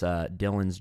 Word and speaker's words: Dylan: But it's Dylan's Journeys. My name is spelled D-Y-L-A-N, so Dylan's Journey on YouTube.